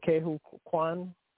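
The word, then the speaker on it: Ke Huy Quan: K. Hu Kwan.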